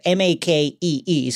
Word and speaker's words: Makee: they don't actually say her name in this, but from IMDb, this is cheating, but M A K E E. So I'm going to say Makee.